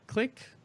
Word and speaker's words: Clique: But yeah, it's spelled CLIQUE, click.